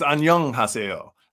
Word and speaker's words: 안녕하세요: Oh, actually I said it wrong. It's 안녕하세요. That's Korean for hello.